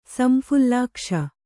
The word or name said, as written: ಸಂಫುಲ್ಲಾಕ್ಷ